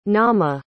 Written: naamah

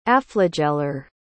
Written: aflagellar

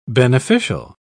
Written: beneficial

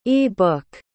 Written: e-book

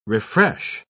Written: refresh

